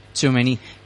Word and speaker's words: Tchouaméni: Tchouaméni, ¿se dice así? – Tchouaméni. – Tchouaméni y Koundé, viejos amigos de su etapa en el Girondins, se retan para la batalla de la Liga.